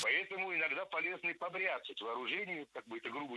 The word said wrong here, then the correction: побряцать: Побряцать неверно. Правильно, побрицать. Это слово имеет настолько непривычное ударение, что большинство людей не слышали и не представляют, что правильно побрицать, брицать.